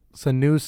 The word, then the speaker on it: Senua: I'm gonna say this name's wrong. Sienna's Sacrifice. It's called. How do you say that? Senua's Saga. Thanks.